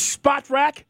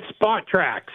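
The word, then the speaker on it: Spotrac: It is Spot Rack. Tell us, is that correct? – It's Spot Track. So it's like take the word spot and the word track and mash them all together.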